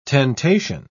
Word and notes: tentation: British English

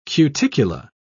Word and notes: cuticula: British English

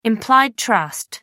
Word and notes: implied trust: American English

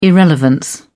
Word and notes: irrelevance: British English

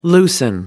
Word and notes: loosen: American English

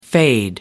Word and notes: fade away: American English

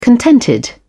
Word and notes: contented: British English

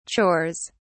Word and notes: chores: British English

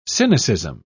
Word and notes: cynicism: English; British English

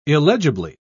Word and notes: illegibly: British English

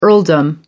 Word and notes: earldom: American English

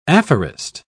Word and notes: aphorist: British English

